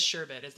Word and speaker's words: sherbet: It is sherbet. There's no other R in there. It's S-H-E-R-B-E-T. Sherbet.